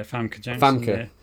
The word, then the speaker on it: Famke Janssen: You got my pronunciation might be off, but Famke Janssen. I believe is the correct pronunciation. – I think it's Famke. Yeah, Famke Janssen.